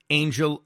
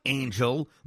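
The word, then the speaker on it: Ángel: Angel Acuna's name wrong? It's not Angel like the team that lost Ohtani. It's pronounced Angel.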